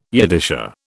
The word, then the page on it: Yiddisher: British English